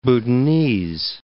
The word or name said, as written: Bhutanese